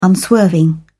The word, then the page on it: unswerving: British English